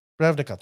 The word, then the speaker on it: Ravnica: You get Voice of Resurgence, which is an old card from back in the day from I, be, I believe uh, Ravnikar. Uh, Ravnik, uh, yeah, Ravnikar. No, yeah, Ravnica.